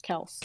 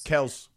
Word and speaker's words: Kelce: Yeah, it's just Travis Kelce saying his name is actually pronounced Kels. – Kels, yeah.